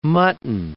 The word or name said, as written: mutton